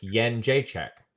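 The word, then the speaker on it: Jędrzejczyk: it's like Asian currency. Try to follow me here, Joe. Asian currency, the first letter of my name, right? And Czech, like Czech Republic. Yen Jacek. That's how I pronounce it, anyways.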